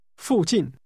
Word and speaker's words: 附近: This is pronounced with two falling tones. Say nearby. – 附近。